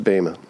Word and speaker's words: Bema: It's actually pronounced Bema. Not Bema. We always say Bema. It's Bema.